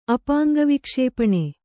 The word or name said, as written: ಅಪಾಂಗವಿಕ್ಷೇಪಣೆ